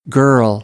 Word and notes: girl: American English; ɝː - ɜː

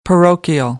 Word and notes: parochial: American English

